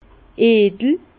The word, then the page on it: edel: Bas-Rhin; Herrlisheim